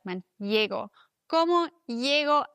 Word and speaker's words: llego: Doppel-L wird wie ein J ausgesprochen, auf jeden Fall im Castellano. Die Südamerikaner machen es manchmal ein bisschen anders. Zum Beispiel die Argentinier sagen Chego. Da wäre Doppel-L ein Sch.